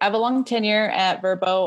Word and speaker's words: Vrbo: Well, we're excited to hear about your experience, and I think I'm saying this right Verbo with formerly known as VRBO. – That's correct.